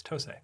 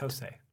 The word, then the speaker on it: TOSE: Actually, I met the Japanese CEO. It's TOSE. – Oh, you met him? – Yeah. – Okay, I'll take your word for it. – TOSE.